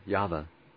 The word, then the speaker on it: Yahweh: Do you put the accent on the first or the last syllable? I don't know if it'd matter. Yahweh or Yahweh? Yeah, Yahweh. With the accent on the first syllable seems better.